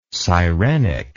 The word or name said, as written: sirenic